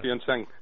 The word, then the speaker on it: Pyeongchang: Pyeongchang. Pyeongchang es Corea del Sur, Pyongyang es Corea del Norte.